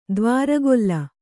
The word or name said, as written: ದ್ವಾರಗೊಲ್ಲ